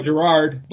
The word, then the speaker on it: Girard: I mean, we always use I got Facebook is just JoJo Girard. Last name is spelled G-I-R-A-R-D, and then I'm at JoJo WFGR on Twitter.